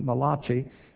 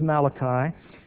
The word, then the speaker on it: Malachi: They pronounced it Malachi, but actually he was Malachi.